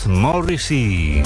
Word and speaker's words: Morrissey: Por eso ha creado este número musical llamado Morrissey, o como se pronuncia en inglés, Morrissey.